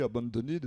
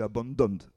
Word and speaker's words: abandoned: On prononce comme ça, j'ai longtemps dit abandonned, et puis un jour un prof d'anglais m'a dit non, mais c'est pas ça du tout, c'est abandoned. Bon, plus difficile à prononcer.